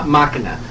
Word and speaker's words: Machina: You know, is that how you say it? – Machina. – Thank you.